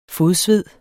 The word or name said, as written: fodsved